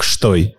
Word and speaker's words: Støy: Но штой а читается изначально как «штой».